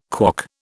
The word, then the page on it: KWOC: British English